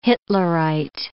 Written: Hitlerite

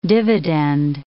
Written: dividend

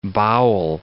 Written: bowel

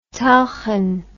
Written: tulchan

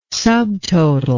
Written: subtotal

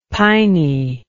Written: piney

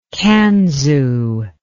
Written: kanzu

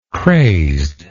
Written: crazed

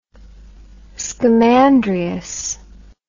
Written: Scamandrius

